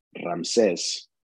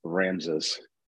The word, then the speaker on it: Ramses: So in Spanish it's Ramses, but in English it's Ramses.